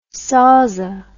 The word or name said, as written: sauza